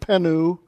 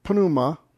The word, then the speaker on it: pneuma: The P and the nu are pronounced together. There's no E between there. It's not penu. It's pneuma.